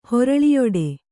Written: ಹೊರಳಿಯೊಡೆ

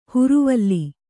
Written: ಹುರುವಲ್ಲಿ

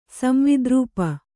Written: ಸಂವಿದ್ರೂಪ

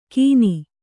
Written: ಕಿನಿ

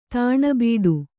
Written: ತಣ್ಬಿಟ್ಟು

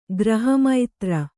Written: ಗ್ರಹಮೈತ್ರಿ

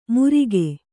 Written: ಮುರಿಗೆ

